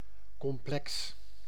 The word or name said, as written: complex